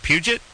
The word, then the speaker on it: Puget: Jade, is it Puget? – That's correct. Good job. Oh, my God. You're the first person to ever pronounce that right. – Yeah. – They put some, like, French flavor. Puget.